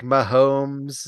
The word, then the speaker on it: Mahomes: Patrick Mahomes. Is that how you say his name? – That is.